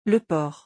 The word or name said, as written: le port